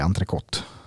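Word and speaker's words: entrecôte: Det, frans, alltså, ska man det franska uttalet är entrecote.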